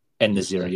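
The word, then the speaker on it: En-Nesyri: Yeah, actually, what do you pronounce it? Enesiri? – Enesiri, yeah. – Enesiri, yeah.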